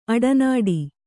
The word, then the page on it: ಅಡನಾಡಿ: aḍanāḍi